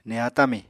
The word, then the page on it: neha tami: Apatani